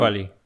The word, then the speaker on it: Kweli: Talib um, Kweli.